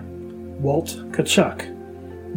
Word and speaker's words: Tkaczuk: Eventually, in the 1969 season, the team made an announcement on the proper pronunciation of his name, correcting the error from years prior. Walt Tkaczuk.